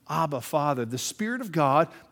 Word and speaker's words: Abba: In fact, say it, Abba. It sounds like dada in English, just phonetically easy to say.